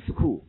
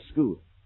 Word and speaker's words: school: 发的这个音，但是呢，你不能说 go to school，应该叫 go to school。